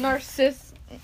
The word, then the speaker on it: Narcissa: Narcissist? I don't know how to say her name. – Narcissa.